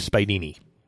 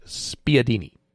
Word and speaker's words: spiedini: Could also be pronounced spiadini. – Oh, well, that Spiadini. – But the primary pronunciation is Spidini. – That is what I believe its primary pronunciation to be.